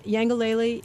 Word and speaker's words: Yangalele: Am I saying that right? – Yes. – And Yangalele in Kikongo means happiness.